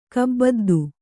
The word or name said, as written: ಕುಬುದ್ಧಿ